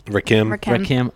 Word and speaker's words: Rakim: and Rakim. – Rakim. – Oh, God. – Like a short E sound.